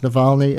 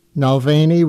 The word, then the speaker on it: Navalny: Never mind that she mispronounced Navalny as Nalvany. We'll forgive her that.